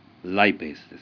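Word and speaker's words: lipase: ですから例えばリパーゼはライペースですよね。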